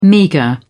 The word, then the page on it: meagre: British English